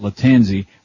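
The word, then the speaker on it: Lattanzi: He reminds me, I'm talking about vocally, reminds me very much of Matt Latanzi, rhymes with pansy.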